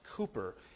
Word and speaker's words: Cowper: It looks like William Cowper, but it's pronounced Cooper, with which many of us are probably familiar.